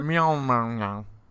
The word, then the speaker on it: Mjolnir: Is that how you say it? Mjolnir? – Mjolnir.